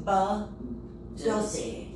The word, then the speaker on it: badoozy: But you have to say badoozy. – Because it's like butt pussy.